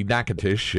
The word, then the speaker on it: Natchitoches: Natchitoches, Louisiana. – You're close. Yeah, most people there call it Natchitoches with a hard C, Natchitoches.